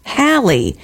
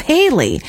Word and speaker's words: Halley: Halley's Comet is actually pronounced Halley instead of Haley.